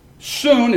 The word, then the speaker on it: sun: Soon is not the sun. It's spelled like a sun in English.